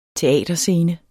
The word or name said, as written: teaterscene